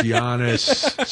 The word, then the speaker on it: Giannis: Oh, it's Giannis. Stop it. Stop it. But I'm going to be honest, every time I read it, he should just put a J instead of the G.